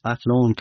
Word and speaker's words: Athlone: founded by Aidan Bond and some colleagues in Athlone, Ireland in 2002. Am I pronouncing the town right? – That's right, yes. Athlone, County Westmead, Ireland.